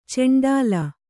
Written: ಚಂಡಾಳ

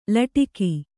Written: ಲಟಿಕಿ

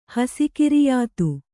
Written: ಹಸಿಕಿರಿಯಾತು

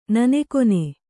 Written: ನನೆಕೊನೆ

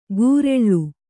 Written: ಗುರೆಳ್ಳು

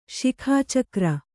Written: ಶಿಖಾಚಕ್ರ

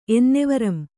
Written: ಎನ್ನೆವರಂ

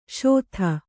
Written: ಷೋದಾ

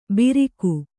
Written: ಬಿರಿಕು

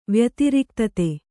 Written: ವ್ಯತಿರಿಕ್ತತೆ

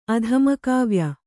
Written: ಅಧಮಕಾವ್ಯ

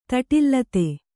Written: ತಟಿಲ್ಲತೆ

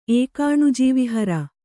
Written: ಏಕಾಣುಜೀವಿಹರ

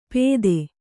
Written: ಪೆದೆ

